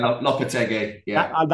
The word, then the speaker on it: Lopetegui: I think we're going Lopetegui, guys. – Yeah, we're going Lopetegui. – That's how William Balaguer is pronouncing it, so I'm going to go with that.